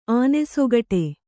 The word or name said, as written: ಆನೆಸೊಗಟೆ